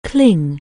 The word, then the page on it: cling: anglais